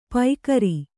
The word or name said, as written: ಪೈಕರಿ